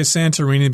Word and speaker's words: Santorini: You might pronounce this as Santorini. But uh, I'm going to say Santorini because that's the way Americans pronounce it if they see the word and it's a Greek island fantasy.